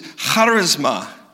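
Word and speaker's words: Charisma (χάρισμα): The other word that's often used is charisma. To say it right, you got to have like popcorn in the back of your throat. Charisma, right? Now we say charisma, right? That's the English transliterated word.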